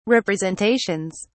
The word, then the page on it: representations: British English